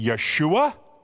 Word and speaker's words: Yeshua: Hebrew pronunciation would be Yeshua, but it means the Lord saves.